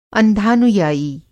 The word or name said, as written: अंधानुयायी